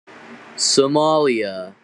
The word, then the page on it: Somalia: English